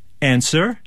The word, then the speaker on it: answer: Answer? – W. – W, yeah. And Neil.